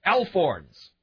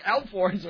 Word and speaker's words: alphorn: A-L-P-H-O-R-N-S. Elf horn. – Wait, spell it again. – A-L-P-H-O-R-N. – Is it two words? – Oh, no, it's one word. Maybe it's alp horns? – But that would...